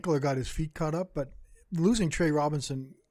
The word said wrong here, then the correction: Roberson: Roberson, sorry. I pronounce it that way in my head.